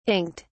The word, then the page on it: inked: British English